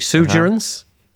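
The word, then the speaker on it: Sugarence: Sugerance? – Yes.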